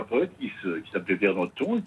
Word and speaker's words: Nothomb: Vous prononcez le B à la fin. – Le B se prononce, oui. – Oui, on dit Nothomb, effectivement. – On dit Nothomb.